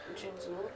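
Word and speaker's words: Jin'xul: When we did face the leader of the Shapers, his name is Jin Zul. – How do you spell that? – J-I-N apostrophe X-U-L. – Oh. – Jinxul. – Wait. Jinxul. You can pronounce X-U-L.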